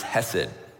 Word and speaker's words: hesed: that is pronounced hesed.